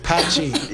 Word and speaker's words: Patxi: Patchy, that's a P-A-T-X-I. – P-A-T-X-I. – But it's pronounced Patchy.